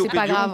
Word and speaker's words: Lithopédium: Damso Lithopédium, on prononce comme ça.